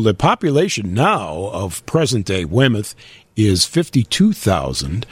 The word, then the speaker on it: Weymouth: We go to the very prim and proper New England colony of Weymouth, Massachusetts. – Weymouth, how do you spell that? – W-E-Y-M-O-U-T-H. Not Plymouth, but Weymouth, Weymouth.